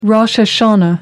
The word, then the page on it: rosh hashanah: American English